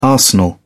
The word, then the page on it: arsenal: British English